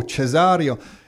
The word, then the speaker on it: Cesareo: How do you say your last name properly, Mario? – Cesareo. – Oh, Cesareo.